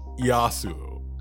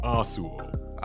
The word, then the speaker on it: Yasuo: So to end this episode, how do you say Yasuo? Because I like to say Yasuo. – I've always said Yasuo. – Asuo. I like to leave the Y. Like, I like to go straight to the A. It's Asuo.